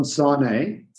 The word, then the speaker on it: Sanei: John Sarnay.